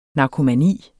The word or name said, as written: narkomani